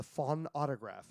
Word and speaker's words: phonautograph: So the reason that you never heard it, because it was recorded on something called the phonautograph. The phonautograph. Yes, I'm saying it right.